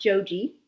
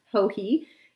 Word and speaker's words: Joji: And I will have you know that on episode 20 of the Yarniacs podcast, we described how to pronounce Hohe, not Joji, which is how we used to refer to her, Hohe.